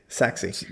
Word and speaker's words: Sachse: Saxe. I got to remember Saxe. Yeah. – Yes. – Which is not spelled anywhere like you would think it would be spelled either way. – S-A-C-H-S-E, Saxe.